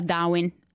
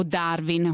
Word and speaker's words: Darwin: a Darwin, o Darwin con la pronuncia italiana.